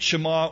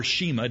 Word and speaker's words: Shema: That, the great Shema or Shema, depending on how you pronounce it, I've heard it pronounced both ways by those who know.